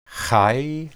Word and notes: hay: Hebrew

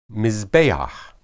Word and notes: mizbeah: Hebrew

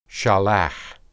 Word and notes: shalah: Hebrew

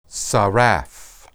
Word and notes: saraph: hebrew

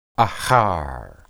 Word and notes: ahar: Hebrew

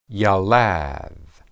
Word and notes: yalad: Hebrew

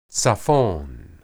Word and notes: tsiphon: Hebrew

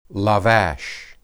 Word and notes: labash: hebrew

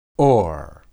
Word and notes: or: Hebrew

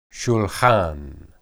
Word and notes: shulhan: hebrew

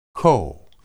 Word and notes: koh: Hebrew

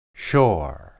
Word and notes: shor: hebrew

